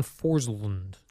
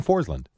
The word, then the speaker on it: Forslund: So Carolina Hurricanes broadcaster John Forslund. – Why do you say Forslund? It's so weird? John Forslund. – Forslund. – You said it right the first time before going Forslund.